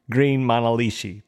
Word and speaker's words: Green Manalishi: The last song we're going to talk about from the Peter Green era is a song called Green Manalishi. Did I say that right? – Yeah, that's how I would say it, with a two-prong crown.